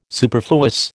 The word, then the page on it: superfluous: American English